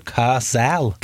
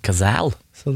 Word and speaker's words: Cazale: Jeg måtte google hvordan man uttaler det navnet. Ja. Men det altså Cazale. – Cazale. Så det, jeg har alltid sagt Cazales, eller Cazale. – Ja, for det en E på sluttene.